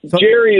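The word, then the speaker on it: Gerry: Nate, is it Jerry or Gary was signed by the team? – Jerry is my understanding.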